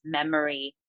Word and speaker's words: Memore: It's M-E-M-O-R-E, but it's pronounced as Memory.